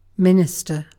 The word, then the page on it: minister: British English